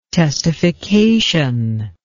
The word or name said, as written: testification